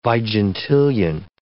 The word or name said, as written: vigintillion